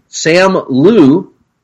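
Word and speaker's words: Simu Liu: Starring Sam Liu. – Is that how you pronounce that? – Yes.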